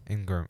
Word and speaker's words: Ingram: Maybe Ingram might be. Did I say his name right? – Ingram.